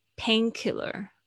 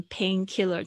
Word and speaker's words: painkiller: 就是基本上都是这样子的组合的时候，比如说像上次我有讲到止痛药是 pain painkiller 哦，不要念成 painkiller，但是它没有错，但是你会发现它重音通常都是在第一个字上面哦，那是重点。